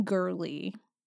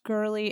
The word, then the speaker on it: Gourley: Haney Gurley, I think it's pronounced Gurley. It's spelled G-O-U-R-L-E-Y. So if I got it wrong, I'm sorry, but I've always heard it pronounced Gurley, so that's where we're going.